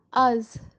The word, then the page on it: az: kashmiri